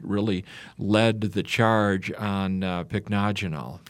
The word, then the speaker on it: pycnogenol: The pycnogenol, is that how that is pronounced? – That is.